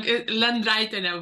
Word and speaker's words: Lendraitiene: no, it, it was wrong. Lendraitiene, but it's you know, fine.